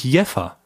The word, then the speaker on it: Jever: Aber dann wurde ich darauf hingewiesen von mehreren Jeva-Einwohnern oder Jeva-Fans, dass es doch anders ausgesprochen wird, nämlich Jeffa. Weil es wird ja geschrieben, J-E-V-E-R. Mhm.